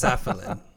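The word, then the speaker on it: Safiullin: Safarian. Safulin. It's Safulin.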